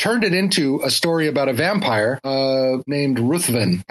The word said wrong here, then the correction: Ruthven: Count Ruthven. – Riven. It's Lord Riven. It's pronounced Lord Riven.